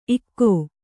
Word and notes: ಇಕೊ: kannada

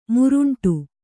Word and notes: ಮುರುಂಟು: muruṇṭu

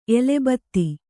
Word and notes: ಎಲೆಬತ್ತಿ: elebtti

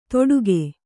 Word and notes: ತೊಡುಗೆ: Kannada

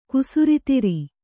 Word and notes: ಕುಸುರಿತಿರಿ: kusuritiri

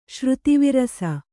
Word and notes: ಶ್ರುತಿವಿರಸ: Kannada

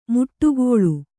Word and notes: ಮಡುಗೊಳ್ಳು: Kannada

